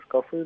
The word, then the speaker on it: шкафы: А еще у меня вопрос, как правильно, шкафы или шкафы? – Шкафы. – А штраф? – Штрафы? – А, слова похожие, а ударение... – Слова похожие, а ударение разное.